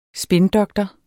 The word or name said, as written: spindoktor